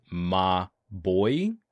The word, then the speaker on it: Mobvoi: So Walt Semarista is saying Mobvoi is pronounced Ma Boy, but that doesn't make any sense. Wouldn't it be like Ma Bois?